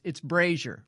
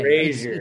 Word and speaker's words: Brasher: So it's Brasher, long A. Brasher. It's spelled as though it would be pronounced Brasher, and I get Brasher. That every now and then.